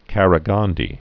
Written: Qaraghandy

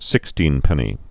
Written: sixteenpenny nail

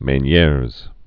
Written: Meniere's disease

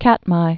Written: Katmai

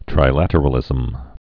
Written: trilateralisms

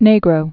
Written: Negro, Rio

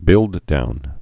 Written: build-down

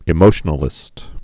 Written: emotionalistic

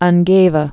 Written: Ungava Bay

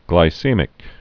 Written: glycemic index

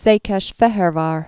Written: Szekesfehervar

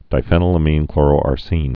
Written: diphenylaminechloroarsine

